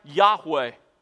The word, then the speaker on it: Yahweh: It's pronounced something like Yahweh.